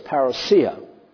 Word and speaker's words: parousia: Then I hear people say parousia, which is all right, but actually the Greek pronunciation is parousia.